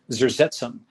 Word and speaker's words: Zersetzung: Like the Stasi had this practice, which I'm probably going to misspell, but it's like Zersetzung. Let me see if I can actually pronounce it. Zersetzung. Okay, Zersetzung.